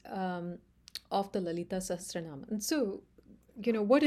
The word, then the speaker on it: Lalita Sahasranama: Living the Lalita Sahasranama. Did I say that right? – Oh, that's perfect.